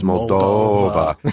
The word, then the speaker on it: Moldova: Now you have to pronounce it properly. It's Moldova. See, doesn't it sound melancholy? – Yeah, it really say?